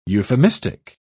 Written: euphemistic

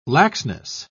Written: laxness

